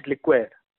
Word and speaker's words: liqueur: One is liquor, that is that is spelled as L I Q U O R, and one is liqueur. Liqueur is basically L I Q U E U R.